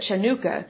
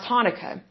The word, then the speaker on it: Hanukkah: So when it's spelled with a CH, it looks like you would pronounce it Chanukah, but it's pronounced with an H, so that's why it's pronounced Hanukkah.